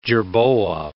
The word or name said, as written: jerboa